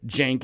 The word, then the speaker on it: Cenk: I feel really badly about it because I know his name is pronounced Cenk, even though it's spelled C-E-N-K.